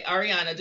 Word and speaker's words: Arieanna: Is it Arieanna? – Yes. – Okay, Arieanna, just want to make sure I'm pronouncing her name right.